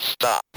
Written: stop

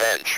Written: bench

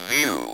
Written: view

